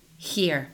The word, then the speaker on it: Heer: con el co-creador que se llama Dr. Dane Here, y se escribe H-E-E-R.